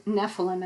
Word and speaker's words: Nephilim: And interestingly, this word Nephilim, and I'm going to spell it so you know where in the Bible it says this word. It's N-E-P-H-I-L-I-M, Nephilim.